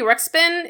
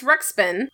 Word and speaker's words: Ruxpin: But our next one, a Teddy Ruxpin. It's Ruxpin.